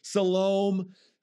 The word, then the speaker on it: Siloam: or different people pronounce it different ways. Siloam, it's S-I-L-O-A-M. Siloam, the pool of Siloam, the pool of the pool of Siloam.